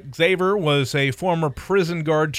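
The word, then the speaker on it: Xaver: It's X-A-V-E-R. Xavier? – It's Xavier? – Yeah, it's Xavier without an I.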